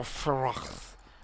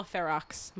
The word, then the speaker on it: Ferox: Or is it Fero or Ferox? – I'm just going to say it's Cannibal Ferox, motherfuckers. Because it's every letter. – Yeah, I'm just saying it. – We don't pronounce silence X's.